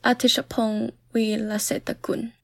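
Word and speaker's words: Apichatpong Weerasethakul: Yeah, this director is a good term to use because the name is Apichatpong Veerasethakula. I have trouble pronouncing this, but maybe our guest can help us with the pronunciation. – Yeah, his name is Apichatpong Veerasethakula.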